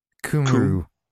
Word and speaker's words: Cymru: I know that the Y, the C-Y-M, that's a Cum noise. Cumru. Cumru, probably.